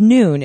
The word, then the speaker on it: Nuun: And I just want to point out it's spelled N-U-U-N. So just like, yeah, you know, I hear, you know, a lot of people mispronounce it a lot. They're just like, you know, Kiwa, you know, it's kind of like sometimes you don't know how to say it, but it's Noon is how it's pronounced.